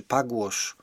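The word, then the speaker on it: Pugwash: do życia ruchu pacyfistycznego Pugłosz, chyba tak to się, czy Pagłosz, tak to się powinno wymawiać.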